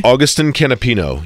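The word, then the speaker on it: Agustín Canapino: Am I saying that correctly? – Augustine Campino. Yep. He is a rookie for Juncos Hollander Racing,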